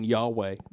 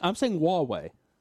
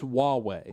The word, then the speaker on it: Huawei: He thinks I'm saying Yahweh. I'm saying Huawei. I know it's got an H in there. It's pronounced Huawei. At least everyone I've heard pronounce it has said it that way.